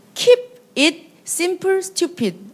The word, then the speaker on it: Keep it simple stupid: 키스라고 얘기하는데 KISS Keep it simple stupid. 발음 괜찮나요? – 네, 네.